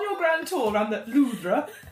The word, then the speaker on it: Louvre: And well, I was at the Louvre. – Oh God! – That is the correct pronunciation.